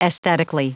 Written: aesthetically

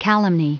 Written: calumny

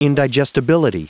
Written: indigestibility

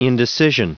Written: indecision

